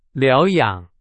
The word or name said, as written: 疗养